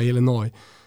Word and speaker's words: Illinois: Nu vet man att superproducent Kim Wirsén sliter sitt hår eftersom du uttalar s i Illinois. – Ja, Illinois.